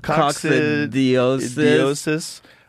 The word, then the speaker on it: coccidiosis: Coccidiosis.